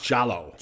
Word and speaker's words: giallo: It's pronounced Giallo. – Giallo. – And the phrase is Giallo. – Yeah, yeah. – I looked it up. I even had the little, you only hit the button and it tells you how to say it. It is. It's Giallo.